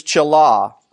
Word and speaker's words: chalah: The Hebrew word is chalah. Let's spell it in English, C-H-A-L-A-H.